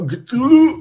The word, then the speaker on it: Cthulhu: Enfin, là, pour le coup, c'est vraiment, je sais pas le prononcer, le monstre de Cthulhu. – Voilà, exactement. Cthulhu.